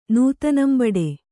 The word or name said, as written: ನೂತನಂಬಡೆ